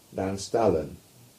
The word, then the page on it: Nanstallon: English